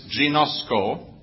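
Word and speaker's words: ginosko (γινώσκω): The two Greek words which he is using are, the first one is ginosko. It is spelled G-I-N-O-S-K-O. Ginosko. I repeat this many, many times today. G-I-N-O-S-K-O.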